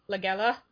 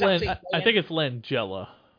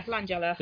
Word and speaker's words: Langella: And obviously Count Dracula himself, Frank Langella, definitely. – I think it's Langella. – Yeah, Langella.